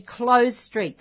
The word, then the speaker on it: Clowes: Now, the address for Melrose is 33 Close Street. That's spelled C-L-O-W-E-S, Close Street, Malmesbury.